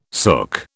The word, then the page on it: sook: British English